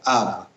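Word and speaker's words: ab (אב): Padre en hebreo se escribe con una alef y una bet, por lo tanto se pronuncia ab.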